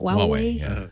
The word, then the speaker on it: Huawei: There's also the new Huawei. Is that how you pronounce it? – Huawei.